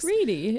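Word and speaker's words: Wrede: Reedy. It's Reedy. Reedy.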